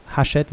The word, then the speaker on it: Hachette: So you wrote a book, Performance Breakthrough, A Radical Approach to Success at Work, Hachette Books, Hachette in French. I don't know how you pronounce it in English. – That's it.